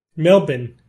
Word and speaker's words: Melbourne: You have not yet nailed the true Aussie pronunciation of Melbourne. You still have one too many vowels in there. Australians are prodigiously lazy with pronunciation. You may hear which vowel it is when you get here.